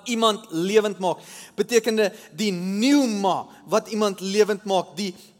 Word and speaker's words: pneuma: Eh, in die in die Nuwe Testament, um, in die Grieks is die woord Heilige Gees is die woordjie pneuma met a, met a, met 'n silent p. P N U. E, p N E U M A.